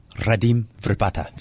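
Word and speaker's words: Radim Vrbata: Radim Verbata.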